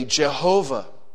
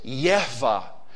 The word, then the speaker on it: Jehovah: And they had to deal with words like Haya and with that Hebrew that we would say Jehovah. You could also pronounce it Yehovah, or even you could pronounce it Yahweh.